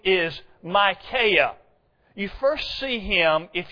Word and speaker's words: Micaiah: Bible students, by the way, that is the way you pronounce his name, Micaiah.